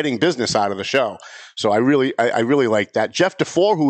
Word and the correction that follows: Dufour: was here, and I said it wrong. It's not DeFore. It's Dufour.